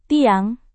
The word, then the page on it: tiang: Indonesian